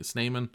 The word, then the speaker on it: Snyman: Apparently Snyman is how we should be pronouncing it.